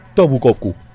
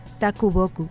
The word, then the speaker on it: Takuboku: Y en 1912 fallece el escritor japonés Ishikawa Tobukoku. – Takuboku.